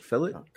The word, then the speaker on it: Chick-fil-A: It's pronounced fillet. We've had this discussion. – It's literally Chick-fil-A. – It's still pronounced fillet. – Well, that's interesting. – Mm-hmm. I know. It's weird, but that's how they pronounce it.